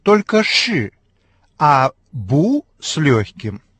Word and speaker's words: 不: Обратите внимание на то, что связка «ши» произносится с четвертым тоном, так же, как и отрицание «бу». Поэтому перед связкой это отрицание по общему правилу получает второй тон. – «Буши!